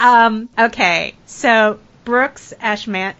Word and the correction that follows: Ashmanskas: I can never say his name, Brooks Ashmanskas from the prom.